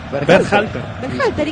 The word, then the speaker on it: Berhalter: Sebastián Ber... ¿Cómo es el apellido? – ¿Cómo? – Dígalo usted. – Berhalter, ¿y qué dije yo? – No, impecable.